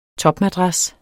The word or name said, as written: TOPMADRAS